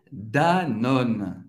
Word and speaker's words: Danone: Danone, en français, est Danone. Danone. Vous va prononcer au N au final. Va acabar no N. Non va prononcer au E no final. Danone.